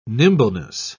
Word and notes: nimbleness: British English

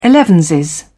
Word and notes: elevenses: British English

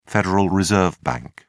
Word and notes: federal reserve bank: British English

